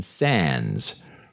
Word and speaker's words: Sandys: For those of you following along closely from home or just looking up stuff on the Googles, Sands is spelled S-A-N-D-Y-S, but it is pronounced Sands, as in the sands of time.